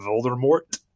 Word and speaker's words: Voldemort: to how you correctly pronounce the name Voldemort, because apparently the T is not supposed to be silent,